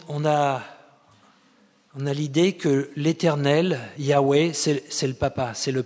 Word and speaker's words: Yahweh: Si ici il est dit, le mot qui est utilisé c'est le tétragramme, le mot sacré des Juifs, Yahweh, que les témoins de Jéhovah orthographient ou prononcent Jéhovah, mais on pense que c'était plus Yahweh.